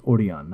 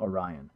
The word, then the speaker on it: Orion: That's right, it's pronounced Orion, not Orion.